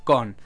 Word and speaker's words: Koon: En el 32 AB, en tiempos de la invasión de Nabu, había lanzado Kun. El Kun abuelo. no, no, Con. se escribe K-O-O-N, así que Con.